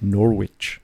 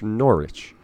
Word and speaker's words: Norwich: I think in Connecticut, it would be Norwich. And in England, it's Norwich. It's because you guys have a stupid accent and you miss letters.